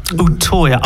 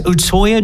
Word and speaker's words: Utøya: Utøya. I think it's Utøya. – Obviously Norwegian. – Yes, Utøya, July 22nd.